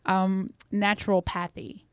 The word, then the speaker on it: naturopathy: It is just not naturopathy. Did I say it right? – Yeah. That was great.